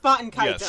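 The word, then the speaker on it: Baten Kaitos: I'm pretty sure it's pronounced Botan Kaitos. – Yes, thank you. – I'm not certain because I don't speak Arabic, but I'm pretty sure it's Botan Kaitos.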